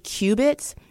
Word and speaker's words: qubit: But I saw the word qubits, and the only way I know of qubits is like how they measured Noah's Ark, and that can't possibly be right.